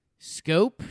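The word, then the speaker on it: Schoop: Jonathan Shoop. Is that how you say it? – Scoop. – Scope. Shoop. Scope. Shoop. – Like it's scope. Like, hey, scope me up some ice cream. Two scopes of vanilla, please.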